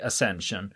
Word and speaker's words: Ascension: Och eh, när jag försökte rota i det här eftersom det är brittiska mm. öar så kör vi Ascension. med det uttalet.